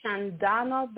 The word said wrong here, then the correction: Shenandoah: How do you say that? – Oh, Shenandoah. Shenandoah Valley.